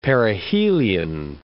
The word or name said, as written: perihelion